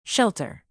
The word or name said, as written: shelter